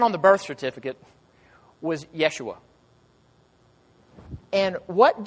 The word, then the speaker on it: Yeshua: That's an English version of a Greek version of his name. That's not what they called him. My pronunciation might be off, I'll be the first to admit, but they would have said something like, "Yeshua, Yeshua." In the Old Testament, we translate that Joshua.